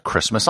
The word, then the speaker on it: Kiritimati: The largest island in the country is called Christmas Island. And how do they spell Christmas? K-I-R-I-T-I-M-A-T-I. Christmas.